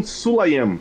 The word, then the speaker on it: Sulayem: Sulaiman ou Sulaiman? Eu nunca lembro a pronúncia do nome dele. – Vamos ver aqui. – É dos Emirados Árabes, que é apoiador ultraferrenho da Arábia Saudita. – Mohammed Bin Sulayem. – Sulayem, isso. A FIA resolveu, e a Fórmula 1 também, resolveram seguir com a corrida.